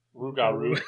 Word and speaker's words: Rougarou: Rougarou. – That's how you say it.